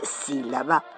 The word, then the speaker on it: sílaba: Porque efeméride y entrabalengua empiezan igual, con la misma síbala que le dicen. – No, Catita, se dice sílaba.